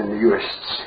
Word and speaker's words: Uist: And this is a rather charming folk song collected in the 30s in the Uists, or Uish, if you want to pronounce it properly.